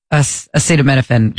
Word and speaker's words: acetaminophen: Acetaminophen.